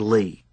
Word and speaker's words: Lee: Your friend Lee. Pronounced like L-E-E.